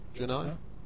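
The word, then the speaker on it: Draenei: Did I say it wrong? Drunai? – No, you said it right.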